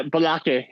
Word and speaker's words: Balake: I have written down the pronunciation of this player's name. I believe it's Balake Malone. – Yeah, Balake. Yep, that's it.